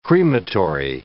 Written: crematory

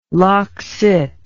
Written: loccit

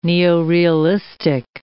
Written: neorealistic

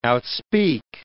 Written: outspeak

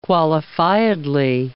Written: qualifiedly

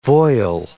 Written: voile

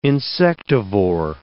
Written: insectivore

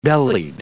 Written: bellied

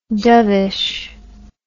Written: dovishness

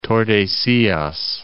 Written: Tordesillas

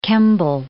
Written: Kemble

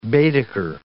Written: Baedeker

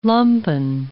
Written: lumpen

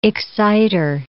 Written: excitor

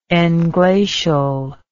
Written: englacial

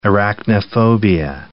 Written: arachnophobia

